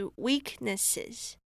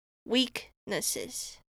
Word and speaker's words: weaknesses: 那我们最后一个发音重点是 weaknesses。那我们这边的重音是放在前面，所以是放在 W 这边 weaknesses。